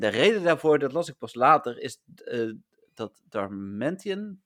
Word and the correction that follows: Darmanitan: zeg ik het goed? Darm Darmanitan. Darmanitan is Pokémon nummer 555.